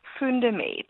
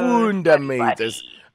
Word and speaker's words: Foondamate: Foondamate. – So Foondamate.